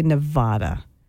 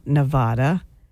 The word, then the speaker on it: Nevada: I hate it when people say Nevada because it's not Nevada. It's Nevada. But Mainers seem to think it's Nevada and they really stick to it.